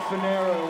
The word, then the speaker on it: Finero: Frank Finero rhymes with arrow on drums.